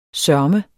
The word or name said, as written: sørme